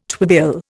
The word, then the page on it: twibill: Indian